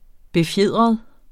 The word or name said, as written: BEFJEDRET